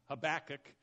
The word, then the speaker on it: Habakkuk: Habakkuk, Habakkuk, yeah, it's got lots of extra K's in there, I think. But Habakkuk is how I will be pronouncing it.